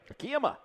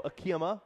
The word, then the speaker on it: Akiyama: Akiyama. – Shogo Akiyama.